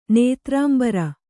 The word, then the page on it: ನೇತ್ರಾಂಬರ: Kannada